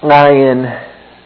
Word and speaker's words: Ayin: You want to swallow, cough, and choke all at the same time as you start your Ayin. It needs to start with your throat closed in the back of your throat.